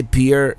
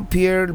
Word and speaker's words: Pierre: I was going to say Pierre, South Dakota, but I've been reliably informed it is pronounced Pierre, or something similar to that.